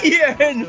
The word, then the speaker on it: Jörn: Jörn. I'm going to spell it. So Jörn is spelled J-U-R-N.